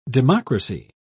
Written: democracy

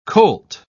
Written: colt

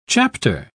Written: chapter

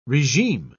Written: regime

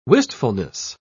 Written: wistfulness

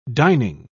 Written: dining

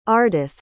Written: ardeth

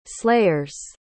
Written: slayers

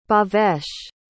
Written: bhavesh

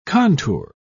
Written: contour

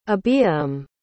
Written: abiyram

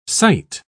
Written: cite

